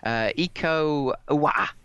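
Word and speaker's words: Iko Uwais: Iko Uwa.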